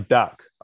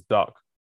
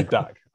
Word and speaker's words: Dack: Bradley Dack. – I always pronounce it as Duck. My bad. But Bradley Dack.